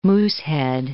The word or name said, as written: Moosehead Lake